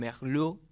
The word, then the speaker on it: Merlot: Merlot para los amigos, la pronunciación correcta se llama Merlot, según docentes.